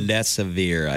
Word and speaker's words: remdesivir: how do you say this again? Red, Remdesivir. Rem, remdesivir is um, remdesivir, I believe.